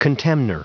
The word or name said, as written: contemnor